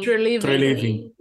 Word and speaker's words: Treliving: A vida inteira pedindo Fire Triveling. – Treliving. – Treliving. Eu sempre troco as sílabas.